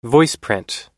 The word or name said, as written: voiceprint